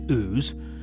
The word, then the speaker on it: Ouse: All that rain, at least along the River Ouse, and yes, it's pronounced Ouse, it leads to a lot of flooding.